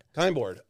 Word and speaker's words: KyndBoard: KyndBoard. It rhymes with a particular after work mood enhancer that some of us like to partake in. Kind bud.